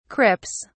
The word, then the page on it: crips: British English